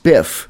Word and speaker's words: XSPF: It's pronounced spiff, and it works in VLC Player and a whole bunch of other programs.